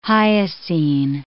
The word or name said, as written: hyoscine